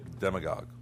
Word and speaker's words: demagogue: Demagogue. – Demagogue. – You pronounce it right. – I thought it was D-M-I-G-O-D.